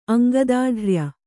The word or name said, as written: ಅಂಗದಾಢ್ರ್ಯ